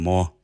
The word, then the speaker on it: Mor: Jeg ved ikke helt, hvordan det skal udtales. – Ja, men her på Nordjysk, der hedder det Mor.